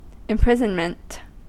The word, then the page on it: imprisonment: American English